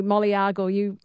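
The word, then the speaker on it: Moliagul: Now my pronunciation was terrible. I'm so sorry, Jenny, who says the pronunciation of the town is Molayagal.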